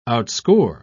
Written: outscore

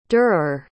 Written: durer

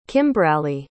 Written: kimbraley

